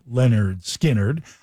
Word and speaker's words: Lynyrd Skynyrd: Skynyrd's Simple Man from 73's debut, pronounced Lynyrd Skynyrd.